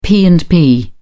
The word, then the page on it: p and p: British English